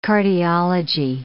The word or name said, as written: cardiology